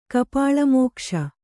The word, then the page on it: ಕಪಾಳಮೋಕ್ಷ: Kannada